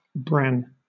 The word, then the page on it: bran: British English